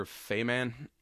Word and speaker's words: Faiman: Like I said, this was a huge movie for the directed by uh, Peter Faiman. That's how you pronounce it never I, heard that name.